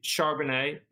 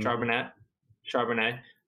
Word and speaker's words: Charbonnet: I could pick Charbonnet. Is that how I would say his name? Mm-hmm. Charbonnet.